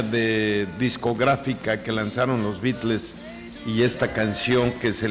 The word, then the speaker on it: Beatles: Laura, ¿cómo se dice en inglés? – Beatles. – Beatles, a ver otra vez. – Beatles.